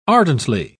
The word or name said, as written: ardently